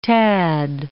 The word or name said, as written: tad